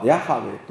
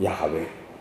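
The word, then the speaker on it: Yahweh: まあ、エホバの証人はそこから来ているわけですけども、まあ、これはとりあえず便宜的な読み方だったので、今はヤハウェという読み方だということが分かって、まあ、ヤハウェというふうに言うわけですが、まあ、聖書ではそれを今太い字で主というふうに書いています。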